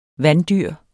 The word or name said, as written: vanddyr